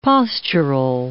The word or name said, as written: postural